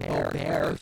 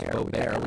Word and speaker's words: Boebert: Radical Trumpist Republican Congresswoman Lauren Boebert, or Boebert, for that pronunciation. Our friend Ro Khanna, for example, prefers Boebert, which I kind of like, it has a satirical ring to it.